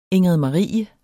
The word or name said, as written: ingridmarie